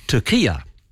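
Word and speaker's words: Türkiye: The new spelling has Turkey with an umlaut over the U, and then it goes R-K-I-Y-E, and this new spelling is pronounced Turkia. Now, I've actually heard Penny Wong and I've heard Anthony Albanese say Turkia, and I presume they're doing it for diplomatic reasons to be nice to them, etc.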